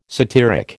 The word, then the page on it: satiric: American English